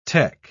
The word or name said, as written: tec